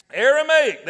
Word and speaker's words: Aramaic: This ossuary appeared authentic and contained human remains and an Aramaic, Aramaic, I can't even say it right, Aramaic, there we go.